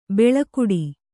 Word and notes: ಬೆಳಕುಡಿ: beḷakuḍi

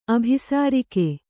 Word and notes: ಅಭಿಸಾರಿಕೆ: Kannada

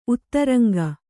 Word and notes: ಉತ್ತರಂಗ: uttaraŋga

